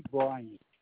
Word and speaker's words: Brian: M-A-L-O-N-E-Y. First name, Brian. – Is that B-R-I or B-R-Y? – B-R-I.